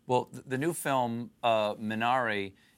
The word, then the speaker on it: Minari: Minari, and how Koreans would pronounce it, Minari.